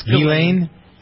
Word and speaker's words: Ghislaine: The same can be said for Ghislaine Maxwell. Do I pronounce her name right? – It's Ghislaine. – It's a silent S.